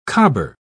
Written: cobber

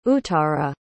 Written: uttara

